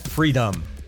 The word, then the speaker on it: Freedumb: It's called Freedom, with emphasis on the final part of that word.